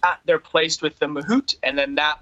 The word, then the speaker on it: mahout: Like I read somewhere that so they get tortured for you know a few weeks, and is that how you pronounce it, Mahout? – Yeah, exactly.